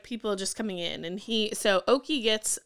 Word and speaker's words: Oke: And then we meet Oki. – Oh, yeah. – I think that's how you say his name. It's spelled O-K-E.